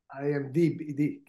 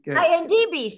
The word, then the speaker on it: IMDb: Can you let our audience know what IMGB means? – IMDb. – IMDb, sorry. I knew I was saying it wrong.